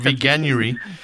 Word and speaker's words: Veganuary: veganuary. – Yes. veganuary.